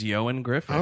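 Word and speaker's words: Ioan Gruffudd: It says his name is pronounced Yoan Griffith.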